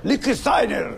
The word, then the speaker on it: Lichtsteiner: Come si pronuncerà? Lichtsteiner. Ecco, questo Lichtsteiner. – Per la pronuncia, fallo di da me. – Ecco, dillo tu allora. – Lichtsteiner. – Ecco, per sì.